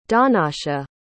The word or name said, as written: donnacha